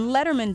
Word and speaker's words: Lederman: It's M-I-C-H-E-L-L-E. Tillis is T-I-L-L-I-S. And Letterman doesn't look like Letterman. It's spelled L-E-D-E-R-M-A-N.